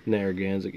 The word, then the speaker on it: Narragansett: Narragansett.